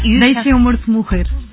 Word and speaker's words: Deixa Meu Morto Morrer: In Portuguese, it's very hard to pronounce Portuguese, but it's almost... – No, you Deixa Meu Morto Morrer.